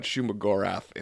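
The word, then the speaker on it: Shuma-Gorath: Shuma Gorath? Squid Boy. – Shuma Gorath, yeah.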